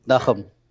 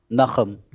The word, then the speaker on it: Nachum: Nachum. – Say it again. – Nachum.